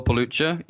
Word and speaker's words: Pelucha: It's spelled P-E-L-U-C-H-A. So if you just go and Google Phil Pelluccia, you will find me.